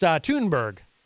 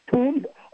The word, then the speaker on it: Thunberg: I believe it's pronounced Thunberg. – It's Thunberg. Oh, that's right. She's Scandinavian. Thunberg, yeah.